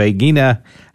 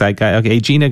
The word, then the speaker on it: Aegina: Aegina, Greece. Aegina, Greece. She said I could pronounce it either way.